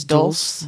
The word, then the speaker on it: dulse: So we're talking about kelp, nori, how do you pronounce this? – I think it's dulse. – D-U-L-S-E.